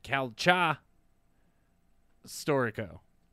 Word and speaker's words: Calcio Storico: It's called Calcio Storico, I think is how you pronounce it. But all I right. also heard another guy pronounce it Calcha Storico.